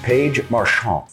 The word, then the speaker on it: Marchant: Joining me now, Paige Marchant.